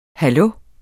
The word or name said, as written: hallo